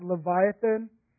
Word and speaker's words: Leviathan: In verse 1, it's actually pronounced Leviathan. It's pronounced Leviathan.